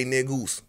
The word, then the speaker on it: Negus: And I say niggas, N-E-G-U-S, which is Negus. So they ain't got to bleep that. You know, mean king. When I say niggas, it's N-E-G-U-S. That's how we pronounce it, but it's Negus. Ethiopian word for king.